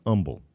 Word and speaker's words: Humble: Did I pronounce that right? Humble? Humble? Humble, it's Texan. I'm a Texan now. It's Humble.